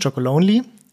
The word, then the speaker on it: Chocolonely: Ich bin 33 Jahre alt und arbeite seit 3 Jahren jetzt bei Tony's, Tony's Chocolonely. Ohne S am Ende, das ist ein Thema der Aussprache.